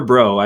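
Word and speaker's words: Breaux: His birth name is Christopher Bro, I believe is how you say it. – Bro. – B-R-E-A-U-X, I believe is how it's spelled.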